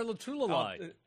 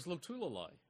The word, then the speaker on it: Lotulelei: I thought it was Lotulelei. – I think it's Lotulelei. – Rhyming with ukulele?